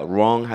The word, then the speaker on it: wrong: 근데 우 발음이 사라져요. 우리가 wrong 하면 WRONG잖아. wrong은 잘못된 건데 잘못된 거는 돌아갔다, 삐뚤어졌다, 틀어졌다라는 뜻이에요.